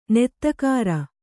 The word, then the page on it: ನೆತ್ತಗಾರ: nettagāra